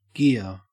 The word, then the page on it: gear: Australian English